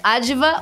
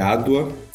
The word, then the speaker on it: Adwa: Aliás, Adva ou Adwa? – Obrigada, pelo convite. É um prazer participar. É, é Adwa, a, a pronúncia e é baseada numa, na história de uma guerra, de uma batalha que aconteceu na Etiópia, a Batalha de Adwa, né, durante a Segunda Guerra Mundial.